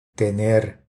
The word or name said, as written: Tener